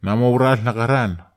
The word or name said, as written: namaural nakharan